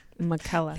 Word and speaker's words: McKella: It's pronounced McKella. Rhymes with Nutella. Oh, okay. – McKella.